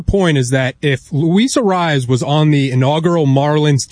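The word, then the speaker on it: Arraez: Arias. – Yeah. I mean, Jesus Christ. But Luis, how do you say it Arise. Again? – Arise.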